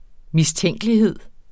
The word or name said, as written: mistænkelighed